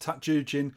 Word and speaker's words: Tatsujin: It's not pronounced like that. It's pronounced Tatsujin.